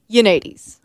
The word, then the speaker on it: Yoannidis: Yoannidis.